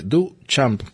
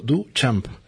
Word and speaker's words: Duchamp: Marcel se escribe con Che, Duchamp, como si tradujéramos del campo, Duchamp.